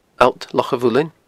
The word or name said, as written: alltlochamhuilinn